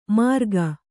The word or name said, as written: ಮಾರ್ಗ